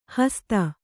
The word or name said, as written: ಹಸಿತ